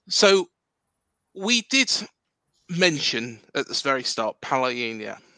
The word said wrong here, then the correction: Palhinha: Um, I've just butchered his name. Sorry, Palhinha.